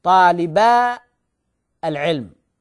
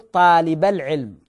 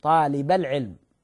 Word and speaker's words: طالبا العلم: طيب، مثاله أيضا طالبان، هذان طالبان للعلم، فنقول طالبا العلم، والصحيح نقول طالب العلم، طالب العلم، طالب العلم، يعني تحذف هذه في النطق لكن يبقى في الكتابة طالبا العلم.